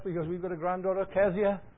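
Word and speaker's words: Cesia: Although you would probably wonder why I didn't pronounce it Keziah, because it's the Welsh pronunciation. There's no Z in Welsh and there's no K. It was a kicking K one time, but it's gone. And so it's C-E-S-I-A.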